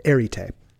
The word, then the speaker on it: arete: Back in college, my professor called it arete. A-R-E-T-E is the spelling.